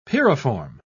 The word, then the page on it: pyriform: British English